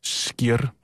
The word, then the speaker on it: skyr: Og så var der en islandsk kvinde, som skrev ind til programmet, at det udtaltes skir.